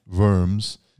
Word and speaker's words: Worms: And worms, as we pronounce it in English, worms. If I was German, the W is a V and the O has an umlaut over it.